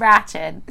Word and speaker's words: Ratched: When they said it, they say it in every episode, her fucking name. I thought it was Ratch.